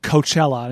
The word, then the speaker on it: Coachella: I think you're 75% correct, because it is pronounced Coachella.